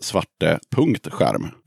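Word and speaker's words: Svarte Skärm: Och just det, och sen så fick jag lite förhandsinformation av dig och då skrev ju du att svarteskärm uttalas svarte.skärm. Jaha. Då... Vad, vad menar du med det? Nej, Min... det menar jag inte. Du, du bara hittade på det. Oj, oj, oj. Som alltså, att det, skulle uttalas särtalat. Nej, nej, nej. Svarteskärm bara.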